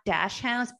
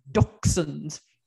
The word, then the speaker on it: dachshunds: It's not dash hounds, it's dachshunds. – Oh, so dachshunds.